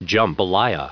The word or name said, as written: jumpalaya